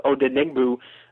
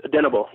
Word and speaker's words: Odenigbo: I actually pronounced it wrong the first time I was asked about him. I thought it was Odenigbo, kind of pronouncing it, you know, kind of how it's spelled. But it's Odenigbo. Yeah, that's how you pronounce his last name.